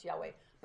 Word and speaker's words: Yahweh: And that G-D is Yahweh. That's how it's pronounced, Yahweh.